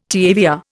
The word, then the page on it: TAVR: Indian